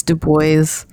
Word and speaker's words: Du Bois: Yeah, it's in the U.S. it's Du Bois. That's how I've heard it. But like, yeah, because nobody pronounces it the French way here.